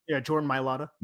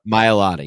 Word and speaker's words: Mailata: Jordan Mailata. – Mailata, yeah.